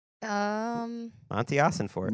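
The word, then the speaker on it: Monti Ossenfort: Colleen is Amante Ossenfort. – Okay. – Is that how you pronounce it? Mossy? – Monty Ossenfort. – Monty.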